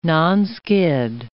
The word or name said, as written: nonskid